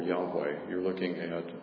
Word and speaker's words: Yahweh: The Hebrew version is, we would have four letters, YH, and I guess it'd be like a V or WH, and I'm not sure that we know exactly how that was pronounced, but it's commonly pronounced Yahweh.